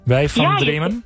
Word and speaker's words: Weg van Dromen: Zeg ik dat goed? Wij van Dremen? – Ja, het is heel goed.